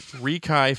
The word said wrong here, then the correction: RiKai: Fanzine. – Right. – That's Rikai Fanzine.